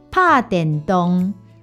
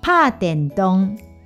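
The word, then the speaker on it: 拍電動: 打电动的台语念作“ 拍电动”，拍电动。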